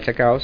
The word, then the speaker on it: Chacao: Esto va a ser en el Centro Cultural Chacao, si lo dije bien, Sabrina.